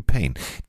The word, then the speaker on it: Payne: Gut, anders geschrieben, aber ausgesprochen wie Payne.